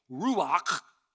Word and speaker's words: Ruach: Especially when you know that this word Spirit, which is the word Ruach, and I've talked about this word before, it's fun to say, Ruach. You don't say it right unless there's spit that comes out and kind of lands on your shirt or your neighbor.